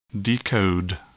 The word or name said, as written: decode